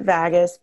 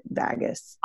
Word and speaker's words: vagus: Is it pronounced vagus? – I pronounce it vagus, but I also have a really weird East Coast Southern enunciation inflection, so I'm not sure. I pronounce it vagus. – I've heard it like Vegas, okay, but like spelled not like Vegas, but I don't know what's what. It's spelled, guys, V-A-G-U-S, if you're like, I want to look that up.